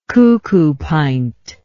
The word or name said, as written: cuckoopint